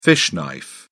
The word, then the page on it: fish knife: British English